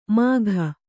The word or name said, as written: ಮಾಘ